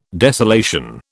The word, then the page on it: desolation: British English